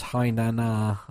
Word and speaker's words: Hainan: Apparently, it's pronounced Hainan, the airport name, not the one that I couldn't do.